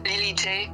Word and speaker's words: Lily J: Donc, mon nom, c'est Lily et mon projet, c'est Lily J. Moi, je le prononce comme à l'américaine. – À l'américaine, oui.